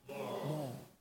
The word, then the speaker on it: moor: Listen, even now, it's the word is M-O-O-R. How do you pronounce it? – More.